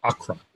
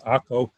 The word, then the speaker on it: Akko: which would look in English like Acre, A-C-R-E, but we pronounce it Akko.